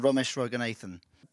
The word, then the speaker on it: Romesh Ranganathan: It's, it's Roisin Conaty and Romesh Ranganathan but easy for you to say.